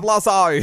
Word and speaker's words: Lizzo: How you say it? – Lizzo. – We love Lizzo.